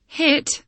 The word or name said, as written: hit